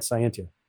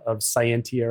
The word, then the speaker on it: Scientia: I've been pronouncing it wrong. – It's okay. We actually have a shirt that says, however you say it, it means good research. And there's like three different pronunciations of Scientia or Scientia or Scientia. So we're all good.